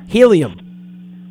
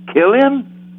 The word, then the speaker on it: Helium: Killian. – Helium. – Gillian. – Helium. – Okay. – You gotta say Helium. – Okay, Killian. – I don't know who's pronouncing a K. Helium with an H.